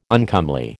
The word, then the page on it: uncomely: American English